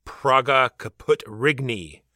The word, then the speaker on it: Praga Caput Regni: And then we're going to talk about our game of the week, which is going to be Praga Caput Regni, pronounced, I'm sure, exactly like that. – Actually, you're pretty close.